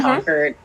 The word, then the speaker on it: Concord: Concord, because you don't pronounce the R, you know. – Concord.